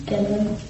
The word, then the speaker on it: Gendlin: I never know if his name's pronounced Gendlin or Gendlin. – Gendlin.